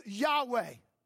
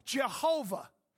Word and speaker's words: Yahweh: Now, the closest we can come to pronouncing that name in English is Yahweh or Yah, or in its English form, Jehovah or Jah.